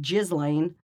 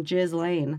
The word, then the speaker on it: Ghislaine: That's how you pronounce Ghislaine Maxwell. – No. – It's not Ghislaine. – It's Ghislaine.